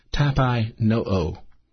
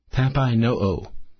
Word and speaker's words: tapeinoo: The New Testament word is tapai no'o. Tapai no'o. That's spelled T-A-P-E-I-N-O-O. Tapai no'o.